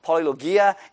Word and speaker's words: polylogia: Many words is this word called polylogia, and it rhymes with diarrhea.